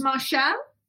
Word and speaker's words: Marchal: And you were called Lynda is it pronounced Marshall? – Yes, very posh.